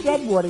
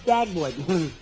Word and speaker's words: Dagwood: My name is not Deadwood, it's Dagwood.